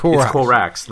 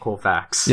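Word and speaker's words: Corax: It's Korax, not Korvax. – Yeah, it's damn confusing. Yeah. Carry on. – They're both very cool. I love them both. They're great. But, Korax doesn't have the same notoriety, same infamy.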